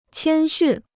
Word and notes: 谦逊: qiān xùn

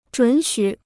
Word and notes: 准许: zhǔn xǔ

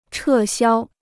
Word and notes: 撤销: chè xiāo

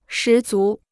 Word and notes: 十足: shí zú

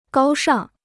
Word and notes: 高尚: gāo shàng

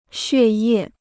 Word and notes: 血液: xuè yè

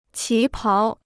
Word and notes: 旗袍: qí páo

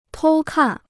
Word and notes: 偷看: tōu kàn

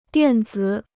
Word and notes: 电子: diàn zǐ

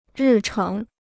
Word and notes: 日程: rì chéng

